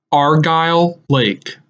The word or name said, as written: Argyle Lake